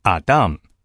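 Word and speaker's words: Adam (אָדָם): 人这个字希伯来文读作 Adam，所以后来中文圣经就特别把第一个人的名字翻译成亚当。我们来看创世纪第一章 26 到 28 节，神说，我们要照着我们的形象，按着我们的样式造人 Adam，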